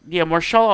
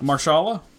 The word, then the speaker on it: Mahershala: How do you say this man name? I want to know it actually, because I feel like I'll always fuck it up. Mahershala Ali. – Yeah, Marshala Ali. I think it's how it's pronounced.